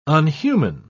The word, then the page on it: unhuman: British English